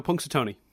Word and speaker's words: Punxsutawney: He goes to Punxsutawney.